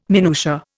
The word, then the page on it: minutia: Indian